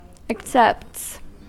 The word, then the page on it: accepts: American English